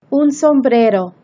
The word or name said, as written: un sombrero